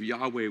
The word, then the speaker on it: Yahweh: The pronunciation in the Hebrew language of Yahweh was a breath. It was more like a breath.